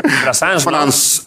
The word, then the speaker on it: Brassens: Brassens.